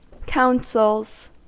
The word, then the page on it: counsels: American English